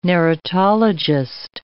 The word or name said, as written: narratologist